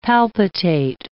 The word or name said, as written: palpitate